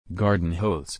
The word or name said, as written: garden hose